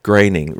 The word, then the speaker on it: Groening: and he would occasionally put in Groening rhymes with complaining, not moaning.